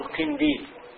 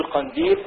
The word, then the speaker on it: قنديل: الخزانة والجراب بكسر الخاء والجيم، والقطع والقنديل بفتح القاف لا بكسر، لا تقول قنديل قل قنديل، لا تقول جراب قل جراب.